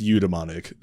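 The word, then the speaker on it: eudaemonic: So So if you asked for the phonetic pronunciation, I would say EU daemonic. But it is pronounced eudaimonic, so yeah. Dumbmonic, even though it is spelled daemonic.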